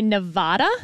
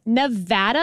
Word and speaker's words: Nevada: was the correct way to pronounce the state that Las Vegas is in. – Mm-hmm. – Because a lot of politicians would say Nevada. – Wrong. – And that is wrong. – Wrong. It is Nevada. And I had to train myself to say Nevapple so I could pronounce the A right.